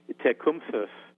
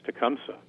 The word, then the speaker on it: Tecumseh: And he, in fact, wrote it out in a memoir phonetically, and he said that the Shawnees pronounce his name, I'll try to do it as much justice as I can, Tecumseh, with a, kind of a TH sound at the end. He suggested that pronunciation was too difficult for most non-Native Americans, and who just simplified it as Tecumseh, just kind of lazily.